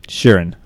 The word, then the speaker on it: Sheeran: I am Sam Sheeran, as you quite rightly pronounced, like Ed Sheeran, but spelt differently.